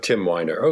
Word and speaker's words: Weiner: And then it's the last name rhymes with Finder. – Okay, Tim Weiner.